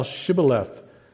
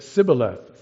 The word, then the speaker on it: Shibboleth: If he said no, then they would make him say now Shibboleth. But he said Sibboleth, for he could not pronounce it correctly. That's interesting, isn't it? They said. So the Ephraimites apparently had their own accent or something that they could not say the SH.